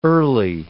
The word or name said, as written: early